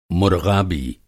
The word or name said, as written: मुरग़ाबी